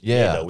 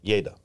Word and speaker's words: yada: So the Hebrew word to know is yada. – We talked about that. – And wait. You say it how? – Yada. – Yada. Okay. – Or you hear it pronounced yada. Yada. Yada.